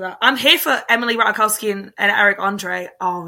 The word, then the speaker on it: Ratajkowski: So Emily Ratajkowski. Is that how you say it? I still don't know how to say her name. – Ratajkowski.